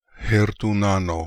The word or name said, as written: Hertoonano